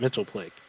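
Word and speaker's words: Mxyzptlk: Yeah, and on Super Friends, Superman pronounced it Mitzelplick. But on Supergirl, they pronounce it Mitzelplittlick.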